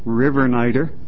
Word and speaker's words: Rivernider: My name's Ken Rivernider.